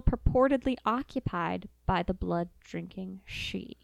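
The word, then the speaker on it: sidhe: It's Gaelic. So it's S-I-D-H-E. – Okay. – Pronounced she. – Okay. – There's also an Irish word pronounced Dracula, spelled D-R-E-A-C-H hyphen, that's Drac, and Hula being F-H-O-U-L-A, which means bad or tainted blood.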